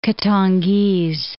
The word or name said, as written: Katangese